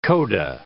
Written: coda

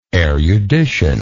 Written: eruditional

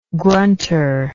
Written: grunter

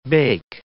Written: bake

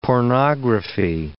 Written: pornography

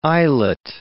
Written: islet

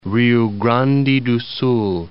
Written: Rio Grande do Sul

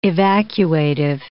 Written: evacuative

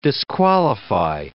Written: disqualify

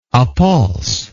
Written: appulse

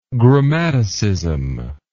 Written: grammaticism

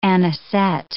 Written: anisette

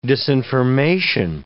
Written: disinformation

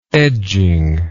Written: edging

